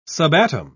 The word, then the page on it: subatom: British English